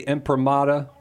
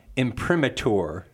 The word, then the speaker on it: imprimatur: And yes, it's pronounced the imprimatur, but it is. It looks like imprimatur. That's how it's spelled there.